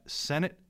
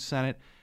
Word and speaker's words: Sennett: But during her time at Sennett Bathing Beauties, which is where she goes after Fox, and I think I'm pronouncing that right. It's it's spelled S E N N E T T.